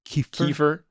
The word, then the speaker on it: kefir: Kiefer? – Kiefer? Well, how do you pronounce it? – I thought it was Kiefer. – That sounds right.